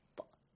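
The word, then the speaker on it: طا: How do you say the letter طَا? طَا. – طَا. – Put your tongue. – Yes. – On the back of upper teeth.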